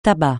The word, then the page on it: tabac: francais